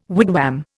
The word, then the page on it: wigwam: Indian